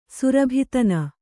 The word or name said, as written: ಸುರಭಿತನ